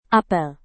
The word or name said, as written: apel